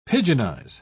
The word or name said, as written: pidginize